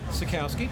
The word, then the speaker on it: Sutkowski: All right, this is Reese again, and I got Todge Sutkowski. Sutkowski? – Sutkowski. – Sutkowski. Okay, I said it right.